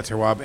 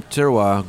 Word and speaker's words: terroir: So yeah, the term terroir, goût de terroir, which is spelled T-E-R-R-O-I-R.